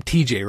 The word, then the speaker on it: TeJay: It should be Tehe, maybe. But because it's T-E-J-A-Y. I love the fact that it's TJ, but it's spelled T-E-J-A-Y.